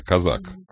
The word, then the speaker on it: Казак: Нет, я думаю, потом козак говорит ему, иди-ка сюда, казак, который теперь, он здесь все-таки образовалось ударение, казак, да, Дмитрий Казак, я имею в виду.